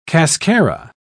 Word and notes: cascara: British English

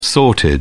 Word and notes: sorted: British English

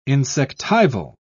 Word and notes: insectival: British English

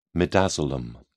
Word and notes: midazolam: British English

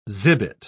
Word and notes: zibet: British English

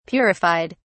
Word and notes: purified: British English

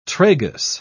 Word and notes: tragus: British English

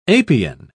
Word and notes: apian: British English

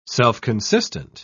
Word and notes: self-consistent: British English

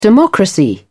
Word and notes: democracy: British English

